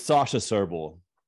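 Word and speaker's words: Sasha Srbulj: My guest today is Sasha Serbel.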